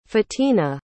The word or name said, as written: fatina